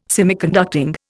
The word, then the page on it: Semiconducting: Indian